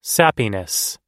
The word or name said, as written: sappiness